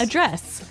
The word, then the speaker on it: address: So the verb address has the stress on the second syllable.